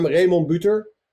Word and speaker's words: Remon Buter: Yeah, I said I'm Remon Buter, which is pronounced, of course, in Dutch.